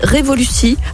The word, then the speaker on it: Revolucy: Et ce matin, pour en parler, j'ai le plaisir de recevoir de l'agence de communication digitale Revolucci. J'espère que je l'ai bien prononcé, Revolucci, à Francheville. Bonjour. – Bonjour, bonjour à vos éditeurs et à vos éditrices. C'est exactement ça, c'est bien révolutionnaire.